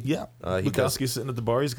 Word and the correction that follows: Bukowski: Bukowski. I keep putting a T in there with Butowski. – It's Bukowski.